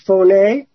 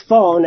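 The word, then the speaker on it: phoné (φωνή): In verse 2 in particular, but the Greek word for voice is phoné, which is spelled phone, and that's the way Rui pronounce it.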